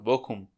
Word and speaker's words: Bochum: Al ah, bohum, Bochum. Algo así. Bochum. Se escribe Bochum, pero se, pronuncia bohum, bohum, Algo así. Ah, Sí. Porque la CH, como hey, una cosa de rara.